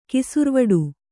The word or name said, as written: ಕಿಸುರ್ವಡು